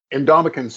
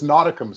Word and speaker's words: Ndamukong: Ndamukong Suh was always a tough one. You eventually got it, but it was Nautikum Suh for a long time.